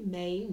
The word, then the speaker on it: May: Okay, my name is Charlie May Nelson. That's spelled M-A-Y.